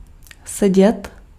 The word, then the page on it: sit: /sɪt/; [sɪt]